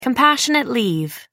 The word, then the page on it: compassionate leave: American English